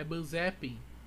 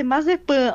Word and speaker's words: Mazepin: Bom, como é que se pronuncia Mazepin? É Manzepin? – Na verdade, a pronúncia correta é Mazepan.